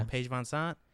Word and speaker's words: VanZant: Paige VanZant.